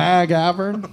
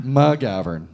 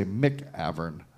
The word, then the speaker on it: McGavern: Well, Nick, am I saying your last name correctly? – You had it right last time. I don't know. You sort of hit the first syllable a little too hard, I think. – McGavern? – Right. It's McGavern. – McGavern. – Yeah. – All right. McGavern. I tempted to say McAvern.